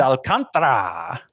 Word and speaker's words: Alcantara: I'm kicking myself because I was calling him Alcantara again. Like it's a pitcher after my own heart, and I can't even say his name right. Alcantara. It's Alcantara.